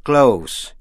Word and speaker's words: close: Nhận xét cách phát âm, tĩnh tự close, C-L-O-S-E, âm S đọc là S, close, nghĩa là gần, động tự to close, close, đóng lại.